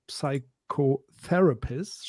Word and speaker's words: Psychotherapist: Und Lea ist Diplompsychologin, ähm, psychologische Psychotherapeutin, gleichzeitig Mitinitiatorin von ähm, Psychologists und Psychotherapists, schwieriges Wort auf Englisch. – Ja, For und das e Future. P spricht man nicht mit.